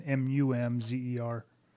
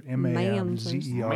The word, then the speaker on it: Mamzer: To find it in the Orthodox Jewish Bible, I had to spell it as Mamzer, M A M Z E R. – Mamzer. – But in the Orthodox or in the Jewish encyclopedia is Mumzer.